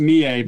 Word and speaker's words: Mie: In You Only Live Twice, who played the main Bond girl? – I believe, well, I think it was Mai Hama. – Yes. – It's close enough. It's actually pronounced Mie, but that's fine.